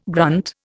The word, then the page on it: grunt: Indian